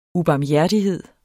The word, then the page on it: ubarmhjertighed: uˌbɑːmˈjæɐ̯diˌheðˀ; uˌbɑːmˈjæɐ̯ˀdiˌheðˀ